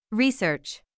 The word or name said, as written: research